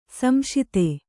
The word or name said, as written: ಸಂಶಿತ